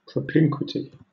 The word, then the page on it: propinquity: British English